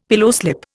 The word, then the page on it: pillowslip: Indian